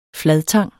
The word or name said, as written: fladtang